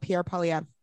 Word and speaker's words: Pierre Poilievre: And when you come back, we're gonna talk about Pierre Poilievre.